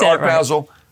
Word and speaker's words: Art Basel: Yes, we're at Art Basel.